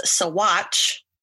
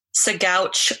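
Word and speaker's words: Saguache: And it is actually pronounced as Sawatch, not Sagouch or Sagoch, like I was pronouncing it.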